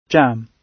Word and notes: jam: British English

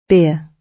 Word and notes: beer: British English

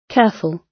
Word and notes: careful: British English

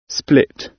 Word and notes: split: British English

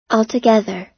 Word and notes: altogether: American English

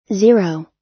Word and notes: zero: American English

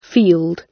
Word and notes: field: British English

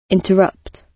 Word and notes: interrupt: British English

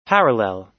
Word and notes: parallel: American English

